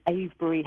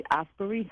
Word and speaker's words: Avebury: So when we look at a wondrous stone circle, and I'm most fortunate to live by, called Avebury Henge, but I love how Americans say Avebury, they say Asbury Henge, and I like that.